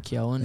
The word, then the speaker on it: Keoghan: Barry Keoghan, is that how you say it? Keoghan. – Keoghan, yeah. And Jacob Elordi, who's in everything now, by the way.